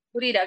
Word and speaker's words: Gurira: Gurira, gracias.